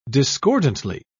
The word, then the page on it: discordantly: British English